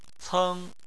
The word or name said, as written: ceng1